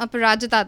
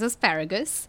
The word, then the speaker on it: Aparajita: I'm Aparajita Datta, and the funniest pronunciation that I've gotten for my name, it's a story that I like to tell, is asparagus.